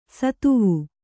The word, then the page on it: ಷತ್ವ: Kannada